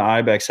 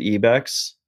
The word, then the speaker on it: IBEX: I'm wearing the IBEX hat. Actually, I found out like we're supposed to be pronouncing it EBEX, but like we've been chilling with the IBEX guys all the time and they just never fucking corrected us.